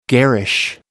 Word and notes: garish: American English

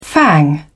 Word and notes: fang: British English

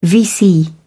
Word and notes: vc: British English

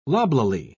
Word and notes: loblolly: British English

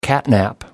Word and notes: catnap: American English

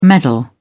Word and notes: medal: British English

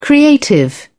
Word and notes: creative: British English

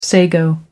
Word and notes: sago: American English

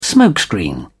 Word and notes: smokescreen: British English

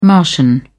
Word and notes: martian: British English

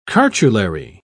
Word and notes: cartulary: British English

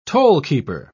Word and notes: tollkeeper: British English

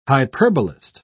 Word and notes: hyperbolist: British English

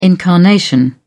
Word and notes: incarnation: British English

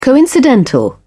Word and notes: coincidental: British English

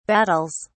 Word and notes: battles: British English

